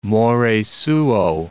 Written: more suo

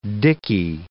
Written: dicky

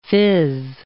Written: fizz

